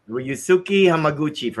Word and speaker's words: Ryusuke Hamaguchi: Then you have Rescoe Amaguchi, I think that's how you say his name. – Ryusuke Hamaguchi for Drive My Car, yeah.